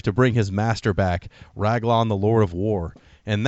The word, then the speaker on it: Raglan: And, in fact, the very beginning of the game has this, like, short story where someone yells, like, his name is Raglan, I think, is how you pronounce it, R-A-G-L-A-N.